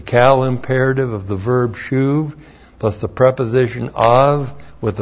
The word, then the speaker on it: shuv: Shuv. This is one of the words in Hebrew for the doctrine of repentance. S-H long U-B. Shuv.